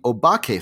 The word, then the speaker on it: Obake: The Obake Files, Japanese pronunciation O-ba-ke,